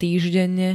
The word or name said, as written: týždenne